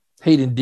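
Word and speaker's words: Haiden: Had a good weekend, and he is looking like Yamaha has a gem in I believe it's pronounced Hayden Deegan. Hayden. – Yeah. – Yeah, it is Hayden. Hayden Deegan.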